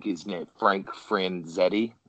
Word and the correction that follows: Frazetta: I think is how you pronounce it. Oh, he did a lot. Frazetta. – Yeah, Frazetta. That's it.